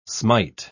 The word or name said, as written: smite